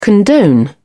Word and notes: condone: British English

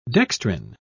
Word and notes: dextrin: British English

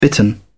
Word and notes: bittern: British English

